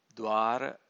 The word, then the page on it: dvara: Pali